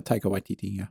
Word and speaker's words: Taika Waititi: It's a, I'm going to butcher this name, Taika Waititi. – Taika Waititi.